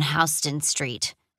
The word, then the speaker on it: Houston Street: Evan and I saw that at the Angelica, an artsy movie theater down on Houston Street, which I mispronounced like it was the city Houston.